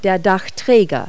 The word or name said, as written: roof rack